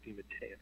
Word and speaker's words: DeMatteis: Although you didn't mispronounce my name because there are two different pronunciations. – Oh, okay. – And a lot of people know me as DeMatteis, but over the years I've come to prefer the official Italian pronunciation, which is DeMatteis.